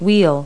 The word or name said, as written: wheel